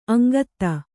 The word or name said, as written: ಅಂಗತ